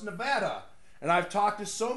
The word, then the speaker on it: Nevada: It is Nevada. That's how you pronounce it.